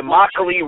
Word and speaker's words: Immokalee: And I thought, Immokalee rhymes with broccoli.